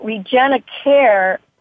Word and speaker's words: Regenicare: Um, and so Regenicare is, it rhymes with repair